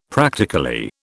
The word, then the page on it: practically: British English